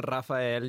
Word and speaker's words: Rafael: So my name is Rafael. You can roll your R's if you feel sexy.